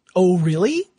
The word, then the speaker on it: O RLY: So it looks like it's O'Reilly. That's yes. But it's spelled O RLY.